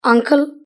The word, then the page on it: uncle: British English